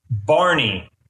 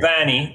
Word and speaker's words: Barney: We would say Banny in New Zealand. That was a bit of a, you know, we'd say Banny. So, and the word is spelled B-A-R, as you say in America, N-E-Y.